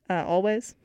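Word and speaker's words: Alvvays: Always. I always used to pronounce it Always because it's like the two V's.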